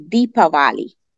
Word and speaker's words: Deepavali: How do you the Sanskrit word is Deepavali. – Okay. – That's the actual way, which means Deepa means a clay lamp, which is really what we call a diya.